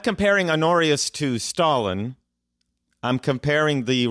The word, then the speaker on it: Honorius: is it Honor- honorius how do you pronounce the emperor I always pronounce it Honorius. – Yeah.